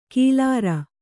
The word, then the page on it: ಕಿಲಾರ: kilāra